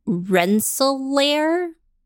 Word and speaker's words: Rensselaer: At the age of 17, he was sent to study at Rensselaer Polytechnic Institute in Troy, New York.